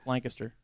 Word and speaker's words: Lancaster: Yeah, that's not how you say it. – It's Lancaster. – Lancaster. – Yeah. – Lancaster. – Lancaster. – Okay. – Yeah, Lancaster. Sorry. Yes. Just put it all one word, Lancaster. Not Lancaster.